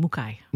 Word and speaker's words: Mukai: It's a Japanese name. It's actually Mukai.